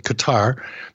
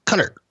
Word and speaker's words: Qatar: Every time I pronounce it Qatar, people would say it's not like guitar, it's Qatar.